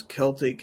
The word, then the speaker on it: Celtic: Abby says, Celtic has the hard C, unless you're referring to the Boston Celtics.